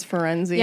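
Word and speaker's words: Firenze: We meet a new centaur named Ferenz. – That's what I call him, because okay. That's how they say it. – Jim Dale says Ferenzi.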